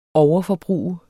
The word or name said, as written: overforbrug